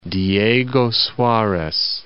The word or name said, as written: Diego-Suarez